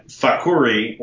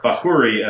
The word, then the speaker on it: Fakhouri: but the Arabic spelling of our name is F-A-K-H-O-U-R-I. We have hundreds of relatives who spell their name that way. And Fakhuri, or Fahuri, as my family pronounces it, translates to Potter.